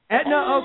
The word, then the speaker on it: Etna: Is it Edna or Etna? – Etna, you got that one right. – Etna, okay.